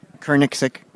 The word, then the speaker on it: Koenigsegg: It's spelled K-O-E-N-I-G-S-E-G-G. – Sorry. Uno más? One more time? – K-O-E-N-I-G-S-E-G-G. G, G. – Oh my God! I don't know. – Koenigsegg, nailed it.